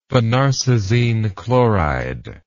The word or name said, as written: phenarsazine chloride